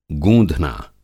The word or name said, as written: गूँधना